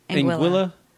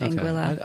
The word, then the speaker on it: Anguilla: Is that how you say it? – Anguilla? That's how I've always said it, Anguilla. – I know it was some like people Anguilla say, or something. – No, it's not Anguilla, but some people say Anguilla.